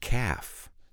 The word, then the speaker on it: calf: Because the word calf has the silent L. C-A-L-F. Calf, we don't pronounce the L. We pronounce the L in calm. We don't pronounce the L in calf.